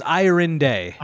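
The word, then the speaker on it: Ayorinde: So it was Iron Day.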